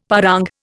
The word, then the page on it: parang: Indian